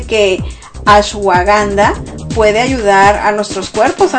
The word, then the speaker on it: ashwagandha: Siempre lo digo mal, pero es el ashwagandha.